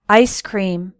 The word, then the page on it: ice cream: American English